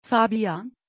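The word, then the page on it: fabian: Turkish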